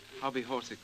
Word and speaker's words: hobbyhorsical: H-O-B-B-Y-H-O-R-S-I-C-A-L. – And will you pronounce it? – Hobby horsicle.